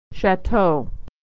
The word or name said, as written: chateau